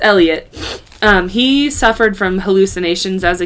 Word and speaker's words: Elytte: That's a big reason why I had to look for a video for her accomplice, because his name is Elliot. But it's spelled E-L-Y-T-T-E. – Oh. – It looks like Elite or Elity.